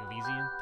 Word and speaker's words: Elysian: I have a pumpkin beer by Elysian. Elysian? Did I say that right? Yeah. – Yes.